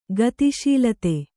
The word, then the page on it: ಗತಿಶೀಲತೆ: Kannada